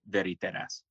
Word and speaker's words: Veritas: So it's Veritas. But in Finnish, you would pronounce it Veritas.